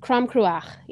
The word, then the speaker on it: Crom Cruach: Crom Cruach, yeah.